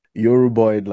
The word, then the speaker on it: Yoruboid: Like, what is Yoruboid language? Well, am I even saying it properly? – Yes, you are. Yes, you are. Yoruboid languages.